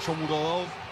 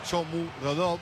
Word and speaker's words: Shomurodov: Caso Shomurodov, así se pronuncia, Shomurodov, es difícil ese.